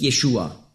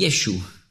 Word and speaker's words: Jeschu: Und warum sagen sie nicht Jeshua? Das ist ja die normale Form für Jesus. Aber sie sagen nicht Jeshua, sondern Jeschu. Also Jeschu schreibt man auf Hebräisch Jod, Shin, das ist bei uns SCH, und ein Vav, ein V. Das wird ausgesprochen als U, das V. Jeschu.